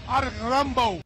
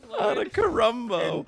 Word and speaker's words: Antetokounmpo: Hey, this Stacey King got me because there's no R in Antetokounmpo. Like, come on, Stacey. Where the hell did R come from? – He totally ejected on the name. He had no idea how to say it, and so he put an R in there.